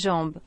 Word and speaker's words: jambe: Pero ¿y en francés? – Jambe. – Jambe, que se escribe jambe.